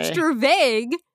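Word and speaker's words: stravage: Stravag, I'm going to pronounce it Stravag because that's the Merriam-Webster pronunciation.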